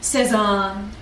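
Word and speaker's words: Cézanne: Like not just Cezanne, but Cezanne with that extra draw that I do on the A.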